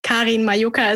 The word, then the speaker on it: Majoka: Karin Majoka is how you pronounce it, but I gave up. You can call me Karen as well.